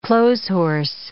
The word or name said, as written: clotheshorse